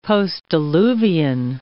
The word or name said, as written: postdiluvian